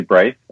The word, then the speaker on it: Braithe: So Blaith, Blaith is that the new one? I, I do get like uh, uh, some interesting um, uh, pronoun- pronunciations um, of my name. Um, uh, but yeah, Blaze is new. That's uh, it's actually Brace.